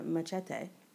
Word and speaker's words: Machete: But actually, Michelle Rodriguez in Machete, and I pronounce it the Mexican way, was really, really, really good.